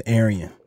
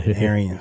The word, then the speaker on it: Erreon: Man, my name is Erreon. You know what I'm saying? – Erreon. I was wondering how to pronounce that. Yeah. – Erreon, straight out of Dallas.